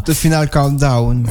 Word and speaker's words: The Final Countdown: The Final Countdown.